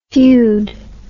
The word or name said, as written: feod